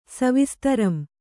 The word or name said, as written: ಸವಿಸ್ತರಂ